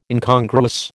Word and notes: Incongruous: American English

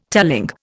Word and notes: telling: Indian

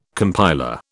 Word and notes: compiler: British English

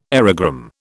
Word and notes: aerogramme: British English

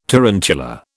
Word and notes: tarantula: British English

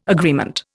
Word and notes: agreement: Indian

